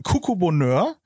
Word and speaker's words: Coucou Bonheur: und das kann man bewundern auf deinem wunderschönen Blog Coucou Bonheur. Habe ich jetzt diesmal richtig ausgesprochen, im Gegensatz es. zum Vorgespräch zehnmal falsch.